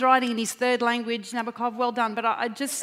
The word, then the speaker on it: Nabokov: Crowd reckons his name's pronounced Nabokov. – It is Nabokov.